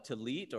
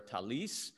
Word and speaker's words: tallit: It was called a tallit or a talis. It was one way to pronounce it.